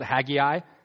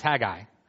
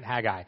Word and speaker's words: Haggai: And a lot of times, for some reason, I think the song needed three syllables at that point, so they call it Haggai. It's not Haggai, it's Haggai, all right? Haggai. But I think a bunch of us as kids learned it wrong because the song needed an extra syllable.